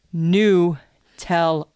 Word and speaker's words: Nutella: Nutella is number three. – Uh-huh. – And it's Nutella. – Not Nutella, it's Nutella. – N-E-W-T-E-L-L-U-H. Nutella. – Nutella. – Yeah, not Nutella.